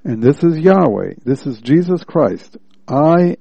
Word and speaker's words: Yahweh: Y-H-W-H, actually. Pronounced Yahweh.